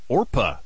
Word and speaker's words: Orpah: She said that on her birth certificate, her God-given name is actually Orpah, you know, from the Bible story of Ruth. Yeah, relatives, though, over the years kept mispronouncing it, and so Oprah just kind of stuck.